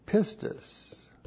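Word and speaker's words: pistis (πίστις): The noun that comes from the verb is just as critically important, is pistis. P-I-S-T-I-S.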